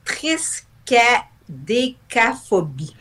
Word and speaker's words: triskaïdécaphobie: On appelle ça la triskaïdécaphobie. – Oui, c'est ça. C'était la triskaïdécaphobie.